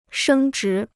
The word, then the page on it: 升值: shēng zhí